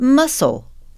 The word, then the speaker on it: muscle: What about muscle? Why the silent C? – Okay, because we have muscular.